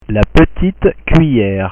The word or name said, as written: la petite cuillere